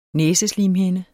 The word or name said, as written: næseslimhinde